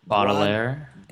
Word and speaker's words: Baudelaire: Baudelaire. – Baudelaire Les Fleurs du Mal.